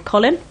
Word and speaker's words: Kolín: So I went to Prague, a small town outside of Prague called Kolín. – Kolín? – Kolín. – Kolín? – Like, it sounds like the man's name, Kolín. – Yeah. – But it's spelt with a K.